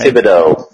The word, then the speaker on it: Thibodeau: Thibodeau.